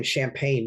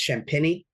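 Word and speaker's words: Champagnie: I was pronouncing his name Champagne, but apparently it's Champagne.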